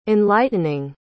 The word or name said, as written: enlightening